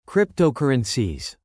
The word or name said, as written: cryptocurrencies